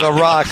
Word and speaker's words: Larocque: Yesterday, I ripped into Canadian hockey player Jocelyn LaCroix. – LaRocque, however you say it.